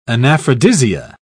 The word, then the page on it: anaphrodisia: British English